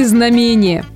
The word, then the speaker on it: знамение: Знамение, да, ударение именно на «а», произошло от слова «знамя».